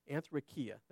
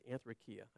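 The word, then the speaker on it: anthrakia (ἀνθρακιά): Now, that kind of tells me, and the word, by the way, is anthrakia, that's what it is, anthrakia. I always say it wrong. Anthrakia.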